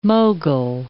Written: mogul

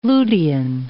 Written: lutein

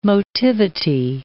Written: motivity

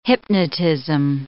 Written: hypnotism

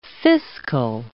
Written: fiscal

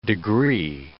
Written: degree

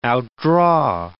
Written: outdraw

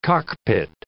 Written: cockpit

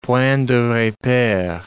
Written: point de repere

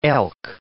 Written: elk

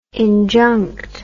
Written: injunct

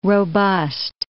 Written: robust

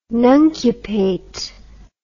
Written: nuncupate

